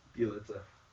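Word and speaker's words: Bjelica: Oh, it's Bielitsa. – Bielitsa.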